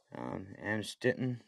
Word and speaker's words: Amstetten: Not Amsterdam, I don't know how to say that word. Amstetten from Lower Austria. How do you say that? Amstetten? – Yeah, I'd say Amstetten. That sounds about right.